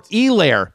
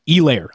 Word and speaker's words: Helaire: Is it Clyde Edwards-Helaire or Helaire? You're saying the H is silent, Field.